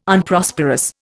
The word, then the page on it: Unprosperous: Indian